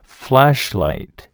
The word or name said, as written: flashlight